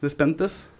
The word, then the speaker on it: Despentes: Virgin Despent. – Despont. Despont. Despentes.